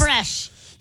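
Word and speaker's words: crème fraîche: Creme fraiche. Did I say that correctly? – And creme chives. Creme fraiche. – Yes, there you go.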